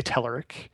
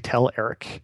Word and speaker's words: Telerik: And so there's two pronunciations depending on, I guess, where you throw the accent. I say Telerik. The Bulgarians tend to say Tel Eric. And I like Tel Eric.